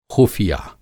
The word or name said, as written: ख़ुफ़िया